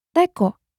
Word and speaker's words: teko: Sure. Teko. Te-ko. This means kettle. Again, teko. So, this is where the letter E is pronounced as E.